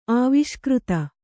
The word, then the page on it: ಆವಿಷ್ಕೃತ: Kannada